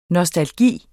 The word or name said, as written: NOSTALGIEN